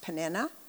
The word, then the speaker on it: Peninnah: And so I know her name is probably, from what I understand, pronounced Peninnah. But if you've been at any of my studies where I've shared this before, you know that I like to call her, yes, Penina, because it sounds so mean and awful, you know.